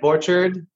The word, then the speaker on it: Borchard: It's like Borchard, but like orchard with a B in front of it.